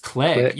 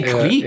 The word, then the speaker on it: clique: Clique is another one, which I think is pronounced click. You say clique? I kind of like clique. – You say clique. Yeah. I think we're just that much closer to France.